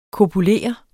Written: kopulere

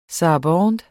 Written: sarabande

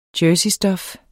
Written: jerseystof

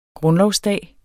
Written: grundlovsdag

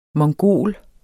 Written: mongol

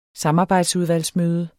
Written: samarbejdsudvalgsmøde

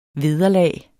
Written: vederlag

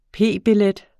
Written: p-billet

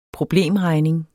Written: problemregning

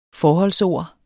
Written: FORHOLDSORD

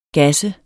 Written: GASSEDE

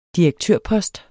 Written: direktørpost

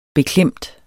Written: beklemt

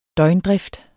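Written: døgndrift

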